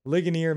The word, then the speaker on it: Ligonier: Who knows how you say it? Ligonier Ministries. – Scott taught me how to say it right.